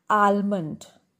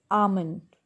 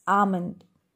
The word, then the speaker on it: almond: Generally we all pronounce it as almond, but it is wrong. The correct pronunciation is almond. It's almond.